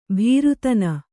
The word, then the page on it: ಭೀರುತನ: Kannada